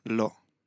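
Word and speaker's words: Lo (לא): Lo. – Lo.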